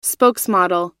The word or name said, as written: spokesmodel